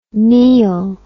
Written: Neill

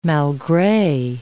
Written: malgre

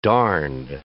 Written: darned

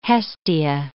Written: Hestia

